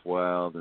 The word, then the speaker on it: Wilde: Well, you look for Rafe Wild, and Wild is spelled W-I-L-D-E, and then in parentheses it says Soldier Blue in there, and that's me.